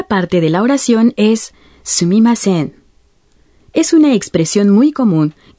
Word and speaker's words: すみません: Sumimasen. – La parte final de se pronuncia alargando un poco la vocal o.